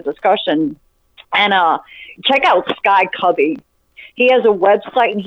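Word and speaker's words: Kubby: Yeah, Sky Cubby.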